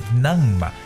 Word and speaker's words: numb: 梳子 comb，坟墓 tomb，包括我们今天所说的这个麻木的词 numb，这个 b 呢是不发音的。